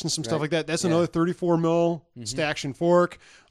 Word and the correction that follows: stanchion: The word for an upright bar, post, or frame forming a support or barrier is pronounced stanchion.